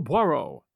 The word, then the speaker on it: Poirot: his name being Hercule Poirot. And yes, I checked, and that's how his name is usually pronounced.